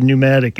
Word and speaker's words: pneumatic: That's, as soon as you use the word pneumatic, any word you use that you don't pronounce the first letter, that's when I get a professional.